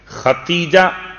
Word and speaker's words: خدیجہ: خدیجہ، شاید اردو تلفظ کا مسئلہ میں نے پچھلے سال بھی آپ کی خدمت میں عرض کیا تھا کہ دال کا استعمال ہے جو محسوس شاید دال نہیں ہو پاتا.